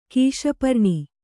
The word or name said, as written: ಕೀಶಪರ್ಣಿ